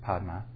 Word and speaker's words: Padme: Like, Om Mani Padme Hum, Mani, Jewel, Padme. It's not actually how it was pronounced. It was Padma.